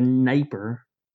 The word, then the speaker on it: Dnieper: So by the 10th century, the largest settlement is going to be along the Dnieper, with a silent D there, I may or may not have had to YouTube how to pronounce that because I had forgotten.